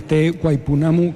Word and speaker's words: Te Waipounamu: nombres impronunciables como Waipounamu, que no sé si lo he dicho bien, que es una región perdida en la isla sur de Nueva Zelanda que vamos a encontrar en este sitio. – Pues mira, Te Waipounamu